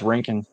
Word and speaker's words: Rankin: R- Robert, say your last name. Is it Rankin? That works. Yeah, it's Rankin.